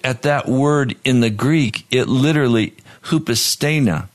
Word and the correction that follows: hupostasis: H-O-O-P-O-S-T-A-S-I-S. Hupostasis.